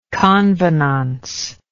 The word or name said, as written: convenance